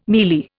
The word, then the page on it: mealy: Indian